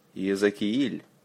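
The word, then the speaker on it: Иезекииль: Ezekiel. Accent on the second letter E. Kiel. Don't forget the soft L.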